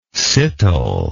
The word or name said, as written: citole